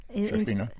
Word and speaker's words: Shekinah: Shekinah, yes.